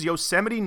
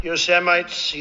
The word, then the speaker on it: Yosemite: Hey, Trump mispronounced the name of California's Yosemite National Park at a press conference yesterday, calling it Yosemite. Yes, listen to this clip. Yosemites, Yosemites, towering.